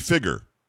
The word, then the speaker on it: figure: I mean, if you listen to Rush for any amount of time, you know that we Americans mispronounce the word figure. It's actually figure. – Sure, yep. – It really is. Exactly. There's no Y in the word, and there's no Y in the pronouncer.